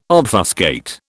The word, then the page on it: obfuscate: British English